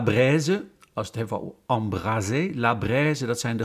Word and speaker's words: embraser: Embraser, met een enkele S, is niet te verwarren met embrasser, met een dubbele S. He, je weet, één S spreek je uit als een Z en twee S'en als een S.